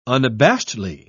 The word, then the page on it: unabashedly: British English